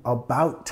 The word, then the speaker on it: about: Yo digo, about time, cuando ya llegas, como ya era hora, ya era tiempo que llegaras, ¿no? About, nota la primera sílaba acá, sonido schwa, about, about time, it's about time.